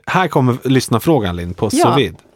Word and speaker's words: sous-vide: Det handlar ju om, hur uttalar man det här? Sous-vide. – Sous-vide. – Nej, jag ska ta bort det jag sa. Sous-vide. – Sous-vide, ja.